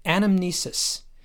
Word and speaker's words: anamnesis: The Greek word is anamnesis, which kind of sounds like the word amnesia.